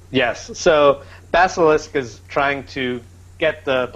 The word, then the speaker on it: Basilisk: How did you pronounce that? – Basilisk? – Basilisk? I gotta work on that.